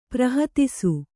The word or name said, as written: ಪ್ರಹತಿಸು